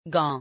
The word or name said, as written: gants